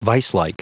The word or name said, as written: viselike